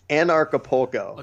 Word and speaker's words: Anarchapulco: How do you say that? – Something like that. It's Anarchapulco. – Okay. – It's an anarchist event that happens in Acapulco, but okay. It takes a few tries to get it pronounced right.